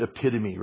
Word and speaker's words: epitome: You guys know the word epitome, right? Well, the way it's spelled, I think, is E-P-I-T-O-M-E, epitome. I didn't know that that's how it was pronounced.